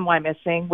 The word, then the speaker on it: Wyomissing: I live I live in Wyomissing, which by the way you don't uh, pronounce the O in Y O Missing.